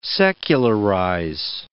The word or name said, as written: secularize